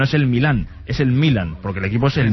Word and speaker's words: Milan: Y el AC Milán es un nombre de equipo, el Milan, es como el Real, el Milan.